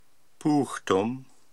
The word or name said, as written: Puuhtum